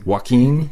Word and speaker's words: Joaquin: and I have the lead singer here with me, Maria Joaquin. – Joaquin. – Yeah. Damn it! I knew I was going to butcher it.